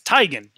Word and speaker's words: Teigen: Her last name is Teigen. You know that, right? – Yes. – Yeah, she let so many people pronounce her name wrong.